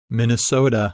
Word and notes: Minnesota: English

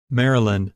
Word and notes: Maryland: English

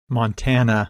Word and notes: Montana: English

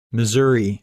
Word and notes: Missouri: English

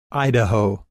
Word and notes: Idaho: English

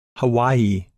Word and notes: Hawaii: English

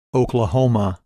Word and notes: Oklahoma: English

